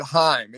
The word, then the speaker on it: Haim: I believe that they pronounce it Haim. It is Haim.